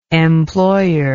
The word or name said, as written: employer